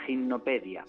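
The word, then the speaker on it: Gymnopédie: Gimnopedia con Y escrito. Sí. – Y así el que quiera, gimnopedia la primera, ¿no? Es como como la Wikipedia, pero de un gimnasio, ¿no?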